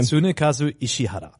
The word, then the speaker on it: Tsunekazu Ishihara: Tsunekazu Ishihara.